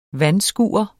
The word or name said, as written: vandskure